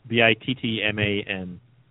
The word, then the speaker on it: Bittman: That's how you say his name, right? – I believe so, yes. – What is it spelled? Bittman? Bittman? – Yes, Bittman.